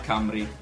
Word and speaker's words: Cymru: Mentre il Galles in gallese si pronuncia Cymru.